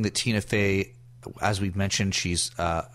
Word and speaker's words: Tina Fey: So we just had a little aha there from Whiskey Tango Foxtrot, produced and starred in by Tina Fey. Did I say that right? – You did.